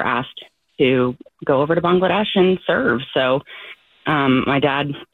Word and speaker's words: Bangladesh: Bangladesh. – Yeah, I'm actually going to correct you, too. It's Bangladesh. – Oh. – Most Americans pronounce it Bangladesh, but it actually is Bangladesh.